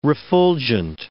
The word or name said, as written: refulgent